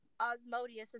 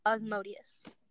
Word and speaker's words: Asmodeus: And Asmodeus is how you say the guy's name with the A. He's the son of lust. Asmodeus.